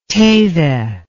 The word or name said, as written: Teide